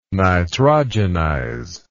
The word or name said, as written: nitrogenize